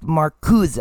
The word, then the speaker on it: Marcuse: It's spelled Marcuse, but pronounced Marcuse, as far as I've been able to find out.